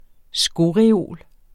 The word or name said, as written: skoreol